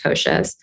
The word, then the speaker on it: Kosas: And we came across this word, which was Kosas. It was based on a Sanskrit word that's pronounced Kosas.